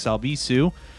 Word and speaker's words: Albisu: You can follow me at Alex Albisu on all the places. Last name is spelled A-L-B as in boy, I-S as in Sam, U.